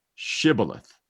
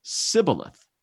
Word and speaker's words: Shibboleth: If he said no, then they would say to him, say now, Shibboleth. But he said, Sibboleth, for he couldn't pronounce it correctly.